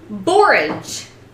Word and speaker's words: borage: It's actually spelled B-O-R-A-G-E, so I looked it up, and and that's what they said. You pronounce it borage. Sounds like porridge.